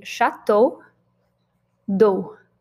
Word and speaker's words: Château d'eau: And it rhymes a little bit, so pretty easy to pronounce. It is simply Château d'eau.